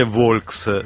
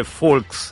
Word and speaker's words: Volks: Ha imparato perché Fuorenda gli ha detto non si dice Volks, si dice Folks.